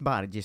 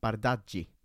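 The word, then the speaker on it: Bardghji: Jag har också lärt mig att han inte ska uttalas Bargi som det stavas, utan Bardaggi.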